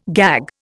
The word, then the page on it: gag: Indian